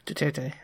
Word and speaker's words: Duterte: The election of, I don't know how to pronounce this, Duterte? – Duterte, yeah.